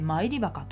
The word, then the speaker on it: 参り墓: 参り墓と読みます